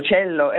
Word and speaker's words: Cello: Udo Lindenberg con Cello o Cello. – Cello, è un violoncello. – Certo, quindi pronuncia non all'inglese, diciamo.